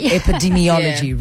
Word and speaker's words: epidemiology: Epidemiology.